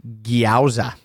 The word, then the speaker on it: gyoza: It's pronounced gyoza.